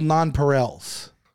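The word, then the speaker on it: nonpareil: Why are they called non-Perels? Oh, okay, there it is. There's a pronunciation right there. Turn it up, man. Non-Perel. It is.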